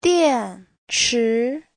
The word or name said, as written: dian4chi2